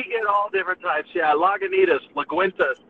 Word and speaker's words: Lagunitas: So give us the official way of pronouncing it. – All right, so you got La Goo Tus. Lagunitas. – Lagunitas,